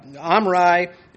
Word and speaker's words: Omri: Omri is proper pronunciation, close as we're going to get it in our language.